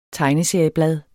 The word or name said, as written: tegneserieblad